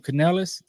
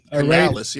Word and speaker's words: Canales: Panthers hired Dave Canales. Did I say his name right? – As far as I know, you did. Canales, yeah.